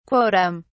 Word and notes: quotum: British English

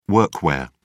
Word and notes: workwear: British English

